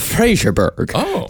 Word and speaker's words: Fraserburgh: Now, Dennis Nilsson was born in the small northeastern fishing town of Fraserburgh, just east of Lossiemouth and Falkenburgh, but not quite as north as the town of John O'Groats.